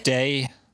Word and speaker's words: DEI-98: This is an Ummite named DEI-98 or DEI-98. How would you pronounce that? DI? – Day. Day. – Day 98? – Yeah.